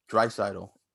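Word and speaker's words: Draisaitl: Dreisaitl.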